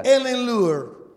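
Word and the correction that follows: Etten-Leur: In Ettenleur.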